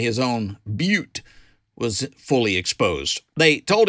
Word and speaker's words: Butte: Okay, now, if you're traveling through the wonderful blue sky state of Montana, keep in mind it's pronounced Butte, Montana, not but.